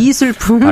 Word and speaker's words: 미술품: 네, 네 경우에 밀수품으로 제가 아, 잘못 아, 요 잘못 죄송합니다. 네, 미술품 발음 네.